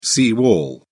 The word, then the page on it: sea wall: British English